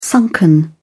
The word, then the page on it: sunken: British English